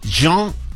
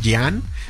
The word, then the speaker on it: Jean: No sé si usted sabía, pero esta marca la fundó René Lacoste y Jean se escribe, para los mexicanos es Jean, pero para los franceses es Jean Redel.